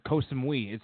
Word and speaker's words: Koh Samui: He was on a lengthy trip on Khao Samui. I guarantee I butchered that. – Oh, Koh Samui.